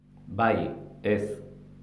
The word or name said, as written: bai ez